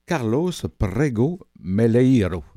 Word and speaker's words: Carlos Prego Meleiro: Alors, l'auteur, c'est un article de Histoire et civilisation de décembre 2022 d'un monsieur qui s'appelle Carlos Prego Meleiro. J'espère que j'ai bien prononcé. – Avec l'accent, c'est bien.